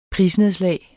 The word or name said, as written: prisnedslag